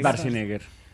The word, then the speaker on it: Schwarzenegger: Es Schwarzenegger. – Es Schwarzenegger.